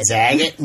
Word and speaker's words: Zagat: I know, I always said Zagat. – Zagat, like Sagat.